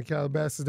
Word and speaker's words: Calabasas: It's been is he on Calabasas, California? Am I pronouncing that correctly? – Yeah, Calabasas, that's where he lives.